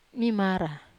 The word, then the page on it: mamara: wamesa